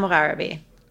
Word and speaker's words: Mogharabi: Shabnam Mogharabi. – Oh, see, I'm glad I didn't say it because I wouldn't have gotten the last name with the right pronunciation. – The right emphasis on the right syllable, right? – Yeah.